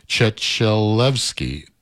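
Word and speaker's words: Trzetrzelewska: Basha's last name is pronounced Chechelevska, sang a tribute to the great Astrid Gilberto entitled Astrid.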